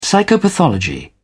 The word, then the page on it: psychopathology: British English